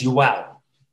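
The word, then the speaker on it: João: Say his name quickly. – Joao Chianca. – It's Joao.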